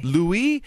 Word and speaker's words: Louis: And there was some actual confusion because it's spelled L-O-U-I-S. We were wondering, so wait, is it the British pronunciation? Is it Louis? – Yeah. – Or is it the French? – Louis? – But it is Louis, nod to another member of the royal family.